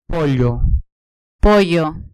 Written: pollo

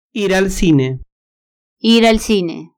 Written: ir al cine

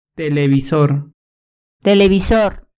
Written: televisor

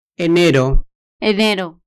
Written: enero

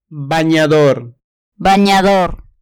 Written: baniador

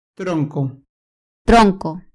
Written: tronco

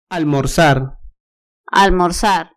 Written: almorzar

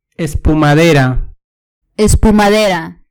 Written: espumadera

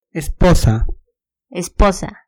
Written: esposa

